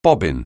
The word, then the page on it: bobbin: anglais